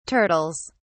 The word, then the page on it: turtles: British English